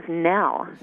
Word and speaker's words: Nil: Well, and it's spelled N-I-L, so you would think it would be Nil, but he says Nell, Nell Sistoon.